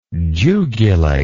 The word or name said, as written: jugulate